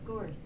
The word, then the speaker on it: gorse: I think it's Gorge. We can Google gorge. – It. – Gorge. – Pronunciation. Gorse.